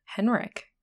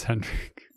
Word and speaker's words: Hendrik: You know, I think it's Henrik. – It's Hendrik. – Yeah. Is it Hendrik or Henrik? – Hendrik. – Hendrik with a D.